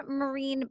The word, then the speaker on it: Myrin: One, if they order now, if you go to Arden Marine Book. Now, my last name is a little tricky. It sounds like marine biologist, but it's spelled M-Y-R-I-N. It looks like Myron.